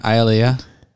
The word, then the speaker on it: Aaliyah: 314 is Aaliyah. Am I saying that right? – Aaliyah. – So no, you could have just said no.